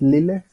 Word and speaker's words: Lyle: Un actor llamado Pablo Lyle, creo que se dice. Bueno, es Lile, se escribe Lile.